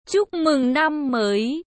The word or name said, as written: chuc mung nam moi